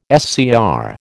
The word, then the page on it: SCR: American English